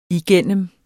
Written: igennem

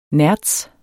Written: nertz

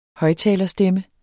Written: højttalerstemme

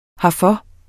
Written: herfor

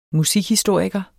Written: musikhistoriker

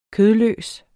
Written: kødløs

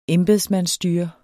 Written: embedsmandsstyre

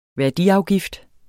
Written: værdiafgift

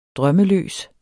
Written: drømmeløs

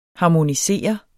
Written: HARMONISEREDES

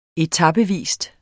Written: etapevis

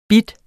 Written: bide

